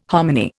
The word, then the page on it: hominy: Indian